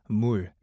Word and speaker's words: 물: But here's my favorite word, and that is 물, M-O-O-L, or it's spelled M-U-L.